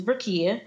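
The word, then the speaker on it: Rukaiyah: Um, so my page is my first name, which is uh, I'm gonna spell it for everyone. It's R U K A I Y A H, which is Rakia dot realtor.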